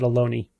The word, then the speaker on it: Ohlone: And the Psychology Club and the Bay Area Skeptics, I guess, are co-hosting our event at Ohlone, which you tell me rhymes with baloney.